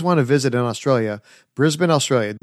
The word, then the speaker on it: Brisbane: Did I actually pronounce it correctly? – That was wonderful because we often get Brisbane, which gives us a little bit of a laugh because we know, uh-oh, they're from overseas.